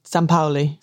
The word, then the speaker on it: Sampaoli: I would say Sampaoli.